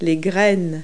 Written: seeds

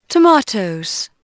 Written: tomatoes

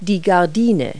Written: curtain

